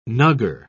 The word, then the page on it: nuggar: British English